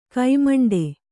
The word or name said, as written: ಘಮೆಂಡಿ